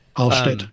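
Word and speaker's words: Allstedt: Well, by this point, Münzer had already been kicked out of one town, Alstedt. Is that how you pronounce it? – Alstedt, Yeah.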